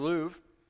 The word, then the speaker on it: Louvre: It's, yeah, exactly, the Louvre.